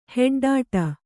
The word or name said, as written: ಹೆಡ್ಡಾಟ